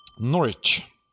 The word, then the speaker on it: Norwich: Norwich. Vet ska inte uttalas, nej. – Testa igen. – Norwich. Hello. – Du kan inte säga vet. Det är omöjligt.